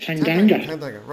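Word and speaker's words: Tanganga: Have I pronounced that right? – Tangi. – Tangi or... – Tangi. – Doesn't matter, we know who you mean. – Not yeah. Tandanga. – Oh, Jaffa Tandanga. – Tandanga, right.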